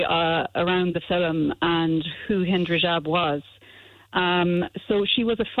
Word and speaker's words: Hind Rajab: Is it The Voice of Hind Rajab? Is that how you say it? – No. You're nearly right. It's The Voice of Hind Rajab.